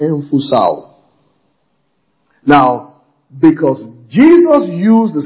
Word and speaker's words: emphusao: It's spelled emphusao in the Greek. E-M-P-H-U-S-A-O. E-M-P-H-U-S-A-O.